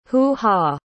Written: hoo-ha

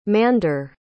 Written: Mander

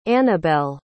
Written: anabel

